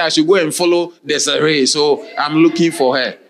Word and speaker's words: Desiree: So the same thing, but if it's a name of somebody, do you understand? You don't pronounce it desire. It's Desiree. Okay.